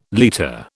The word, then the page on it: liter: British English